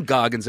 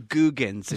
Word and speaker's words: Goggins: And he likes it when you pronounce his name Googans. It's funny for him.